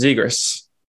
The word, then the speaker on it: Zegras: They put Zegras in the, how do you say his name? Zegras? – I've been saying it wrong, apparently. It's Zegras.